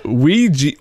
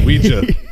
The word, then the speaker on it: Ouija: It's pronounced Ouija, not Ouija. It comes from the French and German words for yes. Oui and ja.